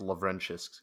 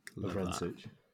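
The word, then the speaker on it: Lovrencsics: All right, L-O-V. – Yeah. – R-E-N, like the Croatian. C-S-I-C-S. It's pronounced Lovrencic, because there's like a real Hungarian player with that name.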